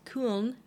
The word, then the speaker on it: Köln: So for an O umlaut, she knew she could pronounce kuhn, and I'm probably butchering it there, but K O umlaut L N, pronunciation of the city of Cologne, as we call it in English.